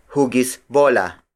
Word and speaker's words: hugis bola: Another variation is hugis bola. Hugis bola. Hugis bola. Hugis bola is spelled as H-U-G-I-S hyphen B-O-L-A. It means ball shape. Bola, the Tagalog word for ball, is spelled as B-O-L-A.